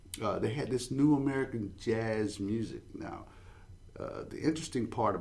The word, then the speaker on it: jazz: See, his name abbreviated is J-A-S, and you pronounce it as jazz.